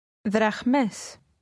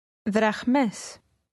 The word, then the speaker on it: δραχμές: Δραχμές. Μες. Δραχ. Δραχ. Δραχμές. Δραχμές. – Did you pronounce the h sound as the speaker did? Say drachmas.